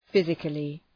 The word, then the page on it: physically: ‘fızıklı